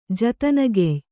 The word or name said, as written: ಜತನಗೆಯ್